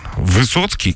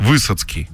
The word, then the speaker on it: Высоцкий: Или ударение неправильно назовут. Где супер очевидно нужно назвать, например, там, Высоцкий, к примеру, да? Uh-huh. Если ты говоришь про Высоцкого. А он называет Высоцкий.